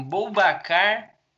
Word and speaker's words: Boubacar: Mano, é bem como se fala mesmo. Boubacar. Tá. Traoré.